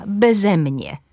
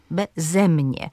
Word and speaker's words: beze mnie: Jeżeli przyimek jest dwusylabowy, to na drugą sylabę tego przyimka, beze mnie, beze mnie.